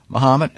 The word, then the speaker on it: Mahomet: Am I pronouncing it? Mahomet, as in Ali, at Yellow & Company.